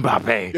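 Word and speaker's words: Mbappé: Kylian Mbappe.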